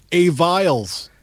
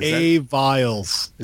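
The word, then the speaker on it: Aviles: At Andy Potter says, how does one pronounce the name of our utility player? – A. Viles. – Oh. – A. Viles. – Is that how you say that? – Yes. Just call him A. Viles.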